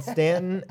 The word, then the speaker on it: Staunton: It's called Stanton, and it's spelled Staunton.